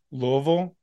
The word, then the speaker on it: Louisville: That pronunciation is Louisville. You got to say it like you're from there. They're very particular about how you say it, like I said last week.